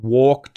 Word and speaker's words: walk: There are words where L appears in the spelling of words like walk, talk, folk, and half. However, the L is not pronounced, okay?